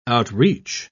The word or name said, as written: outreach